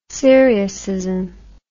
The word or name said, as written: syriacism